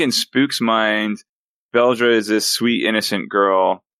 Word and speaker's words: Beldre: Beldre? – Beldre, yeah. – B-E-L-D-R-E.